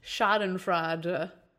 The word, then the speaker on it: Schadenfreude: I have such schadenfreude.